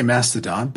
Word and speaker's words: Mastodon: It's Mastodon, right? I always pronounce it Mastodon. – I say Mastodon, but I've heard it both ways a lot.